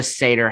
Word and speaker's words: Seder: Which is why the Seder happened on that day.